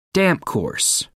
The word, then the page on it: damp course: American English